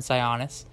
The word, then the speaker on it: Sionis: or depends on where you're from, how you pronounce it, but it's Roman Sionis.